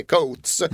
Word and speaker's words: Coates: Hans farsa är skotte, heter Coates, han heter Coates. Men ska ändå uttalas Coates för att så säger de då tydligen Uruguay.